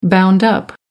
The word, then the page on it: bound up: American English